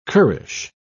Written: currish

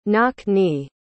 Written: knock-knee

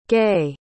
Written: gaye

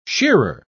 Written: shearer

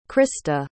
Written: krista

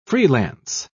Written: freelance

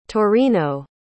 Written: taurino

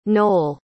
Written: noll

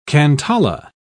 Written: cantala

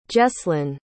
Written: Jeslyn

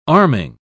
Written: arming

